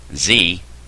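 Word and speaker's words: xi: X-I, Z, that's how you say it, it's not X-I-Z, Z is the pronunciation, means good works or doing good.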